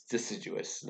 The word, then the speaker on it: deciduous: They are, it's deciduous, not Dicadeus.